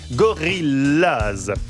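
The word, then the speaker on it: Gorillas: Gorillaz se prononce comme en anglais, Gorillaz.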